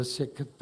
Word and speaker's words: Sikh: She was a member of the Sikh faith, S-I-K-H. And I learned in that interview that you don't pronounce her religion as Sikh, but Sikh, S-I-K-H.